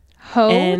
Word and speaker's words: Heaux: I thought it was ho, like H-O-E. – Mm-mm. – But it's not. It's spelled weird. It's spelled like H-E-A-U-X.